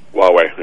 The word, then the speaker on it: Huawei: Huawei? – Huawei, yep.